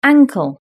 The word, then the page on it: ankle: British English